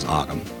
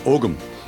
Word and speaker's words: Ogham: The American pronunciation is Ogham. – Ogham. – If you're in Ireland, Ogham. But yeah, we're American, so we just use the American pronunciation of an Irish term.